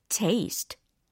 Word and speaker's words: taste: 특히 이 맛에 해당하는 단어의 발음에 좀 더 주의해 주셨으면 좋겠어요. 테스트가 아니라 taste, taste라는 거 하실 수 있겠죠?